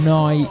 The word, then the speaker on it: Neu: Staremo assieme per un'oretta di novità indie pop e indie rock qui in diretta da Bologna su Noi Radio. Si scrive Neu, si pronuncia Noi e vuol dire nuova, nuova emittente urbana neuradio.it dal sito streaming, dalla pratica app per iPhone e Android.